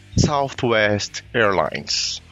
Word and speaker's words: Southwest Airlines: A gente vai direto pro case principal desse capítulo, né, que é da Southwest Airlines. Desculpa aí a minha pronúncia. – Companhia aérea. – CAA, é assim que se fala. – Essa pronúncia tá ótima.